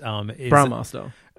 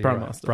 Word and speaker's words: Brahmastra: Brahmastra. – I'm going to call it Brahmastra. – Brahmastra.